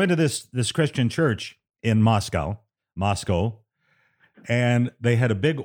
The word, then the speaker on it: Moscow: I had an instructor who also did weekend news on a station in Indianapolis tell me repeatedly, there are no cows in Moscow. It's pronounced, at least to her imagination, Moscow.